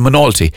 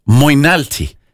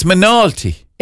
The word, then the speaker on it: Moynalty: and it's called Monalty. Loads of people, when they look at it, they say Moynalty. – So, well, can you spell it for us? – M-O-Y-N-A-L-T-Y.